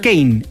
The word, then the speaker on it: Keyne: Es que se escribe K-E-Y-N-E. Ah, pero en la casa le dicen Kane, el hermano de Lamine Yamal.